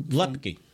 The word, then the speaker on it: Luepke: How do you say his name? You got from. – Lepke. – Lepke. – L-U-E-P-K-E. Just like it's spelled. Lepke.